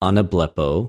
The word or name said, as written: ἀναβλεπω